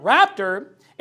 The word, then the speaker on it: rapto: And in fact, you know, the term raptor is used in Spanish to describe the rapture. It says rapto. That's exactly how you say it.